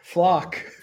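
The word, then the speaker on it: Flach: Is it Leon Leon Flack? Flock? How do you say it? Flock. Flock. Sorry, man.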